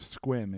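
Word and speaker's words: Sequim: It's on the Olympic Peninsula in the mighty town of Squim. If you don't know how to spell it, look it up. Squim. Is how it's phonetically there, but it's on the Olympic Peninsula.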